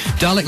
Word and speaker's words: Dalek: Dalek rhymes with garlic.